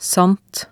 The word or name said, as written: sant